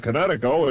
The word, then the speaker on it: Kinetico: Everybody knows that Kinetico is spelled K-I-N-E-T-I-C-O. – I thought the instructor said Connecticut. That means coast. – Son, you should have remembered kinetic as in kinetic energy with an O on the end. Kinetico. – Kinetico. Spell it K-I-N-E-T-I-C-O.